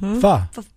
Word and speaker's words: pho: Now, we had a discussion the day about the word in certain restaurants of a certain ethnicity, the P-H-O. – Pho. – But it's pronounced pho.